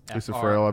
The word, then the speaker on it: Friel: Lisa Friel, I believe. – F-R-I-E-L is how you spell that.